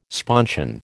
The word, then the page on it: sponsion: American English